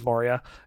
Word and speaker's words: Moria: I don't know if I'm pronouncing that right. It is Moria.